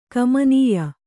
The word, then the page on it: ಗಮನೀಯ: gamanīya